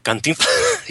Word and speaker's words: Cantinflas: Cantinflas? Yeah, not with a U, with an A.